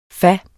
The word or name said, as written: fas